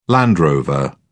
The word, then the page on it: land rover: British English